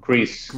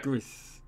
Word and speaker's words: Gris: Gris. – That's it. – Yeah. – Gris.